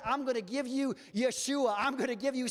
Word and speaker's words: Yeshua: That word is pronounced Yeshua.